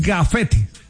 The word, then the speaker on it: gafete: No, porque no es grafetes. – Ah, ok. – Es gafete.